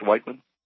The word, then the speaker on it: Whiteman: Then in one place near Whiteman, I pronounced that right, correct, Whiteman? – Correct.